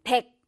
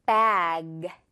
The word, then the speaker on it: bag: 이것도 그냥 대충 백 이래버리면 영어가 아니죠. 이것도 G 울림. Bag. G. 이렇게 살려주면 돼요.